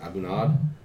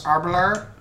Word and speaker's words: Aberlour: I'm drinking my Aberlour. How do you pronounce that? Abunad. – Aberlour.